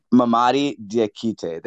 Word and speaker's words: Mamadi Diakite: You pronounced it right the first time. I think it's Mamadi Diakite. – Like, I think his first name rhymes with his last name.